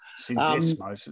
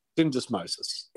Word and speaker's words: syndesmosis: Syndesmosis, um, yep. Syndesmosis, thank you.